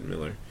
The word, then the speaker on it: Millar: Now, the writer Mark Millar, or Miller, I think they pronounce it Miller.